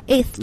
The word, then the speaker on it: eighth: 对，所以发音的时候就没有 t 这个音了，直接发 eighth 就可以了。